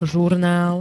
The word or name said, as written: zurnal